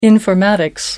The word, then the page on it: informatics: American English